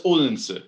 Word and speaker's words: Odense: So so so that's that's Odense, in, and that's how it's said in in modern Danish.